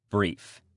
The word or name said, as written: brief